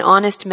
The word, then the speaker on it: honest: He is an honest man. – Honest는 발음 교상으로 H가 모음이고 A 발음이 나므로 모음이 나므로 an을 썼습니다.